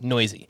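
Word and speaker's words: Neuse: Uh, by the way, if you're trying to figure out how to spell that, that's N E U S E, and it's pronounced noisy.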